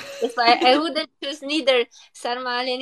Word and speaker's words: sarmale: So the first one is sarmale.